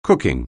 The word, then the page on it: cooking: anglais